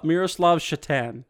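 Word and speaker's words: Šatan: Miroslav Satan. – Miroslav Shatan. Satan or Shatan? I always did the S like sh.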